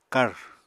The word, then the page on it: kàr: Apatani